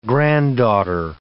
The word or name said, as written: granddaughter